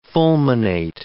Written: fulminate

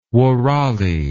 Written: wourali

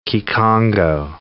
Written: Kikongo

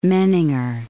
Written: Menninger

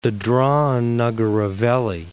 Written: Dadra and Nagar Haveli

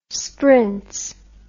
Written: sbrinz

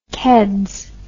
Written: keds

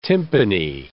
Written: tympany

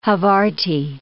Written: Havarti

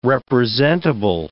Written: representable

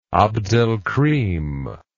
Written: abdelkrim

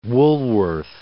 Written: Woolworth

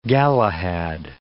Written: Galahad

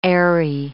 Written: aery

